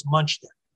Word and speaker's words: Muchnick: You said Muchnick. – I'm sorry. – I think the general. No. That's why we're here. We learned this. The general pronunciation was Muchnick. – Muchnick. Okay. – Just more the way it looks.